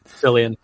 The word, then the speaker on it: Cillian: It's also got Amanda Seyfried and I never know if I'm supposed to pronounce his name Cillian Murphy or Killian Murphy, but it's got him in it. – Cillian. Okay, Cillian. Cillian.